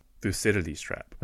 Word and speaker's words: Thucydides: what's it, and what's that thing called where uh, a rising power meets a uh, Thucydian trap? Is that what that? Am I, am I saying that right? Let's look Thuc- this up. Thucydides trap.